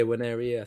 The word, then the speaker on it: Nwaneri: Uh, but yeah, Ethan Nwaneri. That, that I'm saying that right? Yeah, Nwaneri.